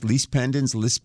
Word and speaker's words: lis pendens: This is pronounced lis pendens, same thing. It's spelled L-I-S. The last word is P-E-N-D-E-N-S.